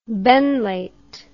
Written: Benlate

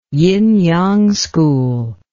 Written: yin yang school

